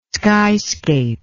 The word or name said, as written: skyscape